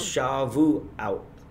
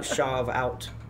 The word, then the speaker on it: Shavuot: It's Shavuot. Shavuot. It's not Shavout. I'm going to shove you out.